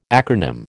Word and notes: acronym: American English

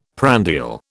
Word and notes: prandial: British English

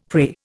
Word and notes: prey: Indian

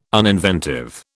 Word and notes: uninventive: British English

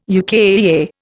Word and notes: UKAEA: Indian

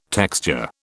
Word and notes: texture: British English

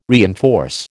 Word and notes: reinforce: American English

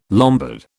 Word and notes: Lombard: British English

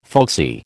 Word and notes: folksy: American English

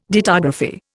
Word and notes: dittography: Indian

